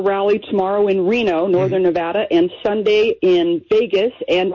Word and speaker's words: Nevada: Are you impressed that I pronounced Nevada properly? – I am.